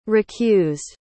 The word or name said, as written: recused